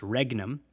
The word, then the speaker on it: regnum: It's R-E-G-N-U-M, but it's not pronounced regnum. It's regnum. The G-N makes that N sound, regnum.